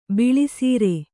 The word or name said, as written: ಬಳಿಸಾರ್